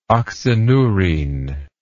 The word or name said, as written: oxyneurine